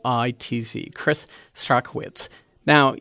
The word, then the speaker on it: Strachwitz: Mr. Strachwitz, which is how his name is properly pronounced, was 91 when he passed away on Friday and left behind so many people.